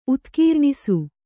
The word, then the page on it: ಉತ್ಕೀರ್ಣಿಸು: utkīrṇisu